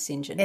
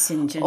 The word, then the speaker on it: St. John: No, it's pronounced St. John. It's St. John.